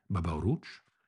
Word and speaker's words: Baba Oruç: C'est simplement à son frère aîné, Oruç, alias Baba Oruç, et prononcé Baba Oruç, et non à une quelconque pilosité dorée que notre homme doit son surnom et son début de carrière.